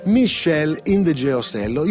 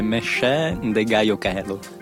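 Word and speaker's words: Meshell Ndegeocello: Io non so se la pronuncia è quella esatta, l'abbiamo studiata la pronuncia, ma continuo a sbagliarla. – Infatti è sbagliata. – E qual è? – È Michelle Indegeosello.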